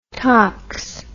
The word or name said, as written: tox